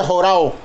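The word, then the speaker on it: jorao (ὁράω): El término ni le ha visto en su original griego se pronuncia jorao en griego, ni le ha visto y significa discernir claramente.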